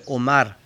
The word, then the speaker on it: Omar: Por ejemplo, su nombre Omar en alemán sería Oma, con una A larga, Oma.